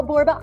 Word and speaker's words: Borba: Yeah, my name is Michele Borba. I'm a 1L girl. Borba rhymes with Zorba.